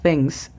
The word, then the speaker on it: things: Pronunciación fonética es J-A-O para how, A-R para are. Things significa cosas. La pronunciación fonética para la palabra things sería TH. Recuerden, ponemos la lengua entre los dientes y soplamos things. T-H-E-N-G-S, things.